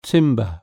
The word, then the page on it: timber: British English